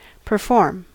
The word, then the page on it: perform: American English